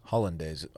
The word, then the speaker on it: hollandaise: Hollandaise. I said it right. – I think you said holidays. – No, I didn't. I said hollandaise. I just said it fast. I know how to say hollandaise.